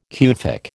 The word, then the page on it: Kufic: American English